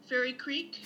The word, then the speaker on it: Fairy Creek: Fairy Creek.